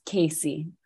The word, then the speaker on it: Casey: Also, I wanted to tell you, do you like Casey or Cassie as the pronunciation of your name? – It's Casey.